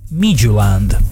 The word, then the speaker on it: Midtjylland: Midtjylland. – Questa è la pronuncia corretta, no, ho capito, ragazzi.